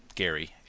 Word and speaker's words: Gerry: And it's derived from his name, Gary, and Mander from Salamander, as you probably know.